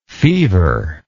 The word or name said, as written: feverless